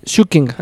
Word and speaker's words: Schücking: Schücking, así se pronunciaría.